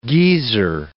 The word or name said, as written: geezer